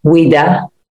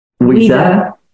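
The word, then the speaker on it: Ouidah: She's also a chief in a village of Oida. I don't know if I pronounced that correct. Correct me. – Wida. – Wida.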